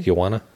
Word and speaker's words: Guiana: Guiana. Am I saying that correctly? – Guiana? – Guiana? – Yeah.